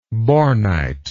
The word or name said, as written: bornitic